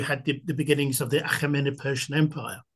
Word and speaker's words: Achaemenid: I heard you say Achaemenid, that's why. I was like, hey, that's me, because I can speak Arabic, and so that is a pronunciation that I don't hear too many people saying.